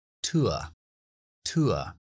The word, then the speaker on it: tour: Entonces, un tour decimos TUA, TUA. Es O-U-R, TUA o la terminación URE cuando no es una T, que siempre sería cha como piccha o nature.